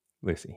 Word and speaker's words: Port St. Lucie: The question to ask yourself in the Keys is Port St. Lucia. What? Port St. Lucie? – Lucy, yeah. Spelled like Lucia.